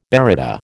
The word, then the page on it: baryta: American English